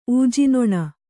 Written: ಊಜಿನೊಣ

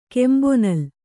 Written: ಕೆಂಬೊನಲ್

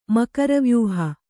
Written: ಮಕರವ್ಯೂಹ

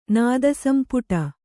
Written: ನಾದಸಂಪುಟ

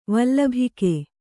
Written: ವಲ್ಲಭಿಕೆ

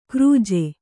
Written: ಕ್ರೂಜೆ

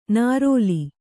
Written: ನರಲೆ

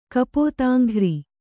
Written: ಕಪೋತಾಂಘ್ರಿ

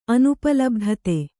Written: ಅನುಪಲಬ್ಧತೆ

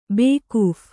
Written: ಬೇಕೂಫ

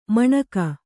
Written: ಮಣಗು

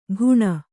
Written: ಘುಣ

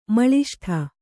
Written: ಮಾಲೆಸೂಡು

